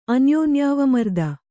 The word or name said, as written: ಅನ್ಯೋನ್ಯಾವಮರ್ದ